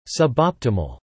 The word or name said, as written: suboptimal